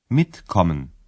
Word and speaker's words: mitkommen: در تلفظ های پیشوندی تکه روی جزء پیوندی است mitkommen